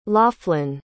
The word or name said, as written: laughlin